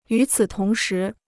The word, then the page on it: 与此同时: yǔ cǐ tóng shí